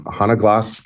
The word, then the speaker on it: Hanna Glass: Hanna Glass, I hope I'm pronouncing her name. – Hanna Glass, correct, and Mimi Larsson, the two Swedish acquisitions.